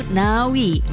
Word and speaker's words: Ngawi: Di Selandia Baru pengucapannya sedikit berbeda, yaitu Ngawi, mengikuti pelafalan bahasa Maori.